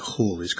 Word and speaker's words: Halley: I think you're supposed to pronounce it Hawley's Comet now, but Halley's Comet that comes around every 76 years.